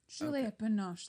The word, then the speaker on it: Juliette Binoche: Juliette Binoche, thank you. – I hope I pronounced that right. That's how you pronounce it, right? – Yes.